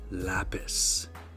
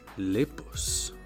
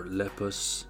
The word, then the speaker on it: Lepus: My previous recording, I pronounced the rabbit slut Lapis. This is an error. Her actual name is Lepus, or Lepus. You know, like lupus, only le, not le.